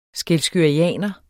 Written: skælskørianer

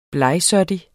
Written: blegsottig

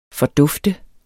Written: fordufte

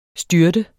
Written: styrte

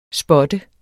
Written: spot

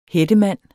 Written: hættemand

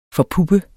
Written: forpuppe

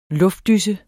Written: luftdyse

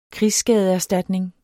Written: krigsskadeserstatning

